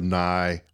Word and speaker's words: nigh: You know, I think there's a reason the word nigh rhymes with sigh.